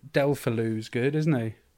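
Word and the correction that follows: Deulofeu: How do you pronounce it, mate? – consult Mark Masters for the correct... – De La Foya. – De La Foya. – It sounds like a cake. – It's certainly not Delphaloo.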